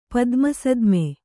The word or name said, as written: ಪದ್ಮಸದ್ಮೆ